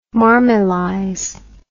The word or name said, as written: marmelize